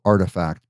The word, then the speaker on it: RTFKT: They acquired a brand, an NFT brand called Artifact, but it's not, it's spelled R-T-F-K-T. So you would never really know that that's pronounced Artifact.